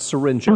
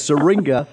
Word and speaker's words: syringa: It's not syringa. Oh. I guess I've seen too many drug needles in Seattle, and I was just thinking the wrong thing here. So it's syringa, not syringa, so that's good to know.